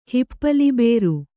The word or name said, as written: ಹಿಪ್ಪಲಿಬೇರು